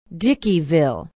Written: dickeyville

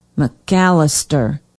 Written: mcallister

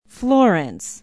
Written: Florence

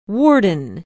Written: worden

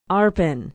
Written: arpin

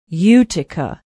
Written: utica

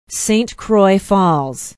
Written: Saint Croix Falls

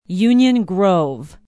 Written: union grove

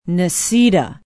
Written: necedah

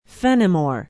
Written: fennimore

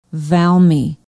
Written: valmy

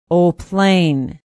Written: eau pleine

